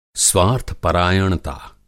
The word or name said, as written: स्वार्थ-परायणता